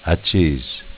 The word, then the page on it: Acces: Dialetto